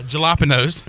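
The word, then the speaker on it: jalapeños: Jalapenos.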